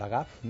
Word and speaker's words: Lagaffe: A més, té un motiu, que és el 50 aniversari del Sergi Grapes, Gaston Algafe, Gaston Lagaffe, no? Es diria en francès.